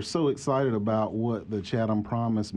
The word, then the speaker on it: Chatham Promise: Am I saying that correctly, Chatham Promise? – Absolutely.